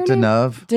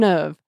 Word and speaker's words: Deneuve: How do you say her Deneuve? – Name? Deneuve.